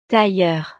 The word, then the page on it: tailleur: frances